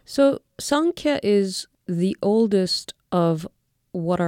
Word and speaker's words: Sankhya: They are Sankhya, Yoga, and Advaita Vedanta.